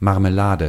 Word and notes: jelly: /ˈdʒɛl.i/

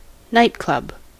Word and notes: bár: /ˈbaːr/

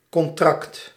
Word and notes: contract: /kɔn.tɾɑkt/

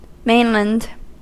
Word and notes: Kontinent: /ˈkɔntinɛnt/

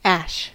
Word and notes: ash: /ˈæʃ/

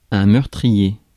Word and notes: deadly: /ˈdɛdli/

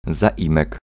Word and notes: pronom: France, Paris; [pʁo.nɔ̃]

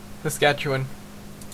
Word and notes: Saskatchewan: /səˈskætʃ.əˌwɑːn/; /səˈskætʃ.ə.wən/

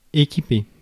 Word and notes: équiper: [e.ki.pe]

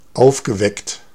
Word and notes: clever: American English; /ˈklɛ.vɚ/

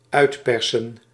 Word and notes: uitpersen: /œy̯tˈpɛrsə(n)/

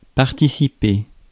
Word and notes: participate: /pɑːˈtɪsɪpeɪt/

